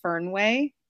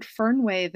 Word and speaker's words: Fernweh: I probably do the American pronunciation and say Fernweh, and it's a German word that means sort of the opposite of homesick, actually.